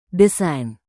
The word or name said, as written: desain